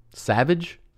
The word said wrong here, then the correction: Sauvage: Is it called? – Sauvage.